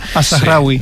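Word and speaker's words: Al-Zahrawi: Pues mira, el primero que he traído es Al-Zarawi, ya me dirá si lo he pronunciado bien o mal. – Al-Zarawi. – Sí. Perfecto, pues lo he pronunciado bien. Mal. Es una buena manera de no decírmelo abiertamente. – Sí, no, bien, bien.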